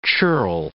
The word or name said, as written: churl